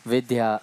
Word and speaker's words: Vidya: Or someone's name, like yesterday, how this whole topic of Vidya arose. – Včera, když bylo to téma Vidya. – She writes V-I-D-Y-A. – Ona to píše V-I-D-Y-A. – But we pronounce it V-I-D-H-Y-A.